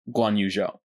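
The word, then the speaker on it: Guanyu Zhou: And I think for my preliminary 5, I'd put down Hamilton, Perez, Yuki, Albon, and Guan Yu Zhou.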